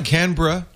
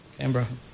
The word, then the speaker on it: Canberra: Thank you also, we must say, to the very quiet and orderly folks from the Canberra, did I say that right? Canberra Grammar School. – Canberra. – In Canberra, Maine. – Canberra. – Canberra. – B-R-U-H.